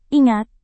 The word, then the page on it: ingat: indonesian